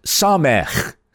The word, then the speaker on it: Samech: We continue with our study of Psalm 119, and we're picking up at verse 113, which is under the heading of the Hebrew letter Samech, or as you would rightly pronounce it, Samech.